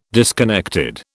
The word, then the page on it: disconnected: British English